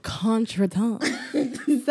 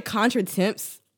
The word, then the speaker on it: contretemps: All right, this is a French word, and it is spelled contretemps. So it looks like contretemps to us American fucks, but it's a French word, contretemps.